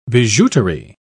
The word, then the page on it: bijouterie: British English